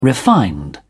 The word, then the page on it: refined: British English